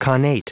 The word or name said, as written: connate